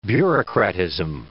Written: bureaucratism

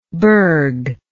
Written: bergh

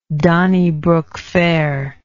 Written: Donnybrook Fair

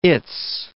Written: it's

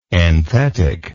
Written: enthetic

